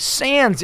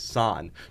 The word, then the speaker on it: sans: But when it comes to French, which is the word without, is sans. So you're still supposed to pronounce it sans. – Okay, dictionary. Sans. Pronunciation. S-A-N-Z.